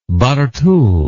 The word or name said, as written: Barthou